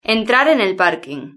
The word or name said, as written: entrar en el parking